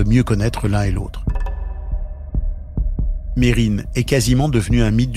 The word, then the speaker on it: Mesrine: Jacques Mérine, comme le prononcent ses proches, ou Messrine, comme le disent de nombreux médias et policiers.